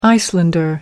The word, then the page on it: icelander: American English